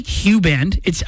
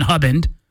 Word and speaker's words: Huband: I'll say now, I like Huband. I think Huband sounds better than Hubband. But if it's spelt H-U-B-A-N-D, I can't be labeled an idiot for calling it Hubband, can I?